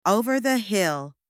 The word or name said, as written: over the hill